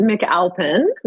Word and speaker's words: McAlpin: It's McAlpin, without an E and without an H. People like to add those, but it's McAlpin.